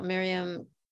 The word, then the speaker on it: Miryem: In the book, one of the pieces about Miryem Crescent, is that the correct pronunciation? – Yes.